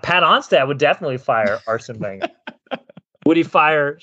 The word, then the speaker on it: Wenger: And producer Ian says Arsene Wenger. I'm pretty sure you pronounce the W a V.